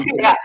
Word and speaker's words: Printify: Printify or Printful, stuff like that. – Yeah. – I said that right, Jesse? – Oh, yeah, that's exactly right. Like on Printify, yeah.